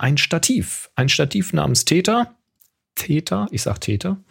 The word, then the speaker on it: Theta: Täter, T-H-E-T-A. Täter.